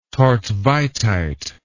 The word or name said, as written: thortveitite